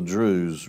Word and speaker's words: Druze: Druze, D-R-U-Z-E. Maybe S-E. Druze, you know, is how you pronounce it.